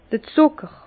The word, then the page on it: d'r Zùcker: Bas-Rhin; Herrlisheim